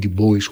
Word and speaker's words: Du Bois: Εκεί εμφανίζονται ο W.E. Du Bois, ή Du Bois όπως είναι η αμερικάνικη γιορτή προφορά του,